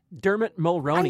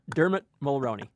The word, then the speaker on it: Dermot Mulroney: Dermot Mulroney. – I never pronounce his name right. To have you do it for me. – Delmont Baloney. I had to do that for Kristen. Dermot Mulroney.